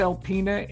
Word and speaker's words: Alpena: So you begin your career at, forgive me if I'm mispronouncing the name, Alpena, Michigan. It was the second smallest market in the United States. So where's Alpena and what was that experience like? – You did well, Alpena, Michigan.